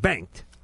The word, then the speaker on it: Bengt: We have Peter and Bengt.